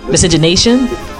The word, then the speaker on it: miscegenation: So there was still a big thing in terms of people talking about what they call miscegenation. I always can't pronounce this word. Miscegenation? – Yeah, I think that's it. – Race mixing. What do we know? Yeah. – I think you got it.